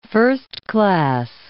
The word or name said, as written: first-class